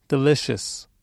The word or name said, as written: delicious